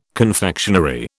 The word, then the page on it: confectionery: British English